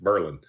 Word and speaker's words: Berlin: Now, it's spelled like Berlin, Germany, but they pronounce it Berlin.